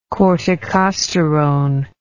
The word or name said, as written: corticosterone